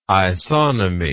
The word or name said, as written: isonomy